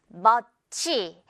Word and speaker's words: much: 자, 이거 발음할 때 많은 친구들이 much 너무나 정확하게 이렇게 두 글자로 발음하곤 하거든요. 그것이 아니라 마지막 ch는 뭐다? 풍선에 달려있는 실꼬리다, 실꼬리. 그래서 ma만 제대로 발음을 하고요. 치는 살짝, 마지막에 살짝만 챙겨주면 돼요.